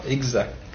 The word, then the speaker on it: exact: Oh, écoutez, actuellement, les deux prononciations existent. Vous ne pouvez pas dire que l'une soit bonne et que l'autre soit mauvaise, voyez-vous. Les deux existent, voyez-vous. Seulement, vous pouvez les juger d'une façon différente. L'ancienne prononciation, si vous voulez, l'ancienne, quelle est-elle? – Exact. – Voyez-vous, sans que te, voyez-vous.